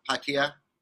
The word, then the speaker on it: Pattaya: Is that the correct pronunciation? – Pattaya. You know, everybody says it a little different. Even the Thais say it a little different. But the Thais say Pattaya.